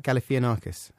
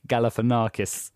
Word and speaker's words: Galifianakis: Zach Galifianakis. – Have you worked on that? – Yeah. – Do you know how I say it? – How? – Zach Galifianakis.